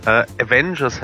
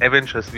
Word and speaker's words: Avengers: Ich habe auch schon was gelernt, glaube ich zumindest, wenn du es richtig gesagt hast, dass es Avengers heißt und nicht Avengers, wie ich es ausgesprochen hätte.